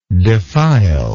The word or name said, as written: defilingly